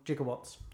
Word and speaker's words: gigawatt: Yeah, the 21 gigawatts. – Yeah. – Which I like to point out, it's just a, it's, he's pronounced it wrong because it's obviously gigawatt.